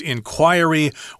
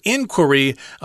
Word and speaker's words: inquiry: And then the noun form is inquiry or inquiry. Uh, you can pronounce it either way.